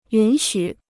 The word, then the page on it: 允许: yǔn xǔ